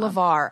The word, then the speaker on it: LeVar: LeVar. I was saying, did I say it right? – I think so. – I hope I did. – Yeah. – Okay. I never know if it's Lamar or LeVar.